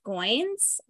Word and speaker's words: Goins: "Real Artists Don't Starve" by Jeff Goins, rhymes with coins, mm.